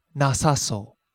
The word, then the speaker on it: なさそう: So instead of na so, we wind up with nasaso.